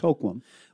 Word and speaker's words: Hoquiam: But the locals that I've talked to, and I've talked to three different ones, and they've all confirmed and said, it's Hoquiam.